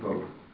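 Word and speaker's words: Bogh: Boat. Well, she's going to introduce herself, so I'll learn how to pronounce her name properly. – I know. Right? – It's Boat.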